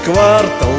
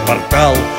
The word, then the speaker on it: квартал: Почти что целый квартал, а правильно квартал.